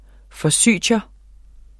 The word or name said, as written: Forsythia